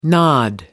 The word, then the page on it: nod off: American English